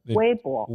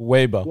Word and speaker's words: Weibo: Weibo, how do you pronounce it? – Weibo. Weibo. Yeah.